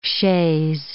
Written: chaise

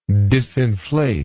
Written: disinflate

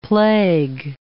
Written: plague

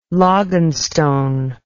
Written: loganstone

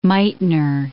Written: Meitner

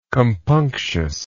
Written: compunctiously

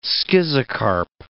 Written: schizocarp

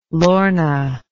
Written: lorna

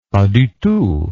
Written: pas du tout

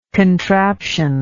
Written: contraptious

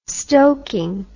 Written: stokin

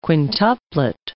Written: quintuplet

